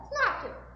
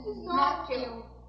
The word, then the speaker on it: snorkel: Snorkel. – Como é que pronuncia? Como é que a gente. Como é que se diz? – Escreve. – Não. É, a gente. Soletra. – S-N-O-R-K-E-L. – Tem R? – Snorkel.